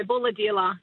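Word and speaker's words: Bulahdelah: Before I say goodbye, on the text line, someone's asking me, how do you pronounce Bulladila? Have I got that right? Bulladila? – Yeah, Bulladila. Yeah, Bulladila.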